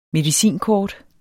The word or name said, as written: medicinkort